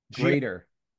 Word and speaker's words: Greater: The Great Oni, Titanic Tim, El Stingray, Mike Hager, Alexander the Great, excuse me, Alexander the Grotter. – Greater. – That's how you spell it? No, Grater's Great, unless someone misspelled this. – No, greater like a cheese grater.